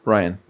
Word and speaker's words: Rian: Okay, now, the last one that we're going to talk about movie-wise is The Last Jedi, which is directed and written by, is it Rian? – Ryan. – Ryan? Well, then spell it that way. – Tell his parents. – Rian Johnson.